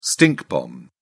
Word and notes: stink bomb: British English

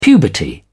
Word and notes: puberty: British English

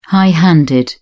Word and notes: high-handed: British English

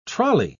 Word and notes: trolly: British English

